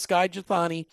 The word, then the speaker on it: Skye Jethani: And we're talking about Luke Bobo's book that he wrote along with Skye Jethani. Am I saying his name right, by the way? That's, that's correct.